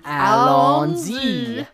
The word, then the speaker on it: Allons-y: Allons-y.